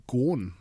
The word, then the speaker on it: Ghosn: Ja, und dazu noch eine weitere Meldung über einen Franzosen, zumindest halb Franzosen, über den ehemaligen Chef von Nissan, Carlos Ghosn, habe jetzt gelernt, spricht man ihn aus.